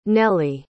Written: nellee

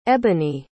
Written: eboni

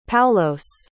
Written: Paulos

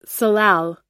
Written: salal

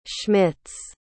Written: smits

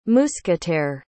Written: mousquetaire

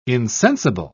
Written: insensible

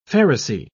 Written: pharisee

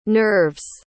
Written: nerves

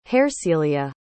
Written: Hersilia

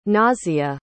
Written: nasya

